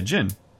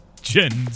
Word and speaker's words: djinn: It's D-J-I-N-N-I. – Yeah, djinn. – Yeah. – The I is not pronounced at the end? – I mean, like, the proper pronunciation is djinn, so yeah.